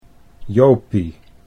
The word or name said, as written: Jopie